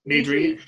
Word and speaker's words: Nedry: Am I saying that right? – Nedry.